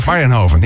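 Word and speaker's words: Mayrhofen: B. Mariehoven? Zeg ik dat goed? – Mariehoven, ja.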